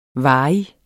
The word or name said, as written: varig